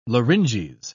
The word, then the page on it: larynges: British English